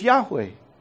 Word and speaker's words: Yahweh: And so our best reconstruction of how to pronounce YHWH is Yahweh. It's Yahweh.